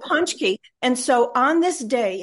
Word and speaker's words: pączki: Now, that makes no sense, but what it is in Poland, it's a big celebration, and they have these donuts called pączki. It's not how it's spelled. It's got a Z and a, you know, Polish letters are crazy.